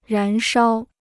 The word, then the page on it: 燃烧: rán shāo